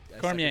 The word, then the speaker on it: Cormier: I'm gonna butcher your last name because I Cormier. – Suck at French. Cormier. – Oh, you nailed it.